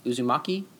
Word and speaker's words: Uzumaki: But this one and then the other one you told me about, Uzu Uzumaki. Is that how you say it? – Yeah, we're the first, and they are fascinating to me.